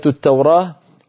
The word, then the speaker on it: التوراة: كلمة التوراة وصلا بالتاء ووقفا بالهاء، لابد من الوقوف عليه بالهاء.